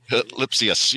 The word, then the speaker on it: Lipcius: Lipsius, yes.